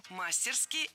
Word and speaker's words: мастерски: Мастерски – это теперь тоже норма. Хотя раньше следовало ставить ударение исключительно на последний слог – мастерски. Теперь это страшное наречие можно произносить по-разному. Мастерски и мастерски – двойная норма.